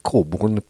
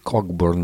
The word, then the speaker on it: Cockburn: Diciamo che ultimamente così, pronuncio esattamente il cognome Cockburn perché è una delle precisazioni che ho letto da qualche parte, quando per anni appunto per noi era Bruce Cockburn, ma la cadenza insomma c'è.